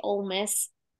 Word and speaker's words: Ole Miss: So in same way, so basically what you're saying is just pretend it's the word hole without the H and pronounce it that way.